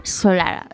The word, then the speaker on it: Solara: Se llama Solara, Solara en pronunciación perfectamente inglesa.